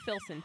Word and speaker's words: Philson: Everywhere I go, I'm like, Philson, P-H.